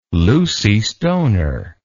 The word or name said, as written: Lucy Stoner